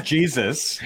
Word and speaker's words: Jesus: Can you pronounce it properly, please? it's Jesus.